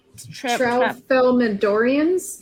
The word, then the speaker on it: Tralfamadorians: The Tralfamadorians.